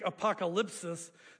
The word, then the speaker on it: apokalypsis: The Greek word apocalypse, which is pronounced in Greek apokalypsis, simply means to reveal, to uncover, to say, hey, here's something under here.